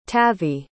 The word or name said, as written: tavi